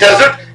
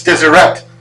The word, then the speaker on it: Deseret: No, no, it's not desert. It's Deseret, because we put an E in it.